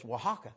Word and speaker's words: Oaxaca: Oaxaca. And Brother Hall started laughing. I think Brother Troy called in or something and said, it's Oaxaca. I said, and why didn't they spell it like Oaxaca?